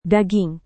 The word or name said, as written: daging